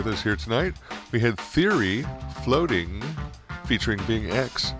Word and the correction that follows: Binks: It's Binks. – Oh, Binks. Pardon me.